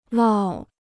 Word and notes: 涝: lào

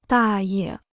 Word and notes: 大爷: dà ye

